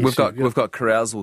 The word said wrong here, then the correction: Carousel: here. – Carousel, actually.